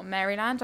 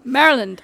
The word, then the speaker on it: Maryland: His story begins in Baltimore, Maryland. I pronounced that completely wrong. – Maryland.